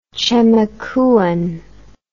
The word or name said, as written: chemakuan